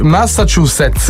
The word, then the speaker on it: Massachusetts: Rocky con le sue mani ha scritto la pronuncia proprio quella letterale. – Massachusetts. Corretto. Sai che me la potrei posso pronunciarlo in un altro modo, quello che sicuramente mi ricorderò.